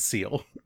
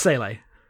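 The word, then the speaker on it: Seele: So is that actually how you pronounce it, because I've always said Seal? – It's Sele. – Okay. – But yes, I think I said Seal as well until I found out how to pronounce it.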